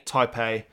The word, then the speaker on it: Taipei: How do you pronounce it? Is it Taipei? – Taipei.